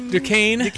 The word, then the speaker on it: Duquesne: And how do you say who BYU is playing? – Duquesne. – Okay, it's spelled weird, but yeah, don't be surprised.